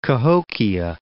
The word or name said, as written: Cahokia Mounds